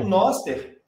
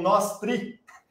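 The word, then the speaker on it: Nostr: O Noster, e não tem jeito certo de falar, tem gaúcho que diz que lá no Rio Grande do Sul eles falam Nostri.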